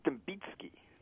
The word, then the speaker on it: Dembicki: It's pronounced Dembicki. – Dembicki. – It throws people off. It's a soft C, kind of like rice or something like that.